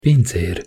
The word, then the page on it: pincer: Hungarian